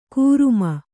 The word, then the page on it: ಘರ್ಮೆ: Kannada